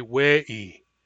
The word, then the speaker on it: we'i: When I say in Barkly Sound dialect, say it, I say we'i, we'i. The word for say is wa with a long a, wa, but when I add the command ending, that a becomes an e, we'i, we'i.